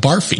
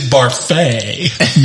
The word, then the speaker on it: Barfee: But his name is spelled B-A-R-F-E-E, and so everybody always calls him Barfy. But he is quick to correct them. It is Barfay, not Barfy. Barfay.